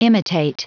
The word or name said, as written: imitate